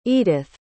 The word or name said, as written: edyth